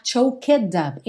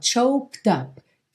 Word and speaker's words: choked up: Cuidado para você não falar choked up. Esse E não é pronunciado. Então, ó, choked up. Treina esse K com o D.